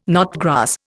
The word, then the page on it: knotgrass: Indian